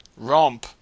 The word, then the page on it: romp: Australian English